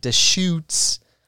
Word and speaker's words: Deschutes: I got Deschutes Fresh Squeezed IPA.